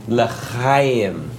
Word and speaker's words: L'chaim: No, Augie, it's L'chaim.